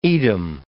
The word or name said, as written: Edam